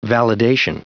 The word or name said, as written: validation